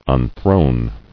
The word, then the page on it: Unthrone: un·throne